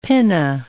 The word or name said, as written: pinna